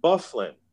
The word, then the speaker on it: Byfuglien: And I thought you ought to know that number 33 pronounces his name Bufflin. I said, you gotta be kidding me. I said, how do you get Bufflin out of that combination of letters? It can't be that. He goes, well, I'm just telling you that that's how he wants his name pronounced.